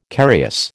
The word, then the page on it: carious: American English